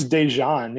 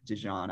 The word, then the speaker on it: DeJean: Is it Dejean or Dejean? – I think it's Dejean. Yeah, like Dejean mustard. It's either Dejean. – Before my time, I don't actually have any memories of Mike Dejean.